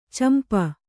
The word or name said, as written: ಚಂಪೂ